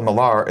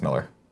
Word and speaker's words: Millar: Even though it's spelled Millar, it's pronounced Miller.